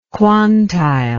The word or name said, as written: quantile